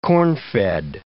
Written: corn-fed

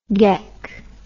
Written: geck

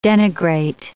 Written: denigrate

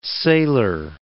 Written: sailor

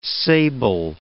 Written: sable

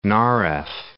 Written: Narew

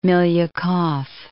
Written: Milyukov

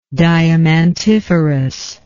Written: diamantiferous

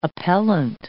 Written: appellant

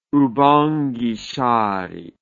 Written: oubangui chari

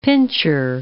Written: pincher

